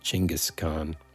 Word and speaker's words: Chinggis Khan: but there's an incredible series of books about Genghis Khan, mm-hmm. who, technically speaking, I think you're supposed to pronounce it Chinggis Khan.